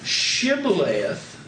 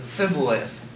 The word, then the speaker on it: Shibboleth: Then said they unto him, say now, Shibboleth. And he said, Sib-oleth. You have the SH versus an S.